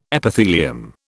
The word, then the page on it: Epithelium: British English